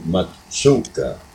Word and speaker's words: Mazzuca: Louis, L-O-U-I-S, Mizuka, M-A-Z-Z-U-C-A. In Italian, it's actually Mazzucca, is what the pronunciation is in Italian. But since in English, why the double Z has a different sound, why we go by that.